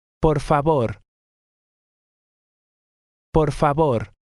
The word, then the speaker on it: favor: Por favor. – Notice that in Spanish, the letter V sounds like our letter B.